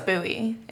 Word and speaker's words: Bowie: Yeah, and I grew up in a suburb called Bowie, Maryland. It's wicked. – Spelled like David Bowie. – Even better. – But it's Bowie.